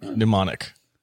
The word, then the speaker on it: mnemonic: Mnemonic. – It's Mnemonic. – The M is silent.